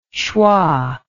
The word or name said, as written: shwa